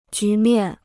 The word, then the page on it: 局面: jú miàn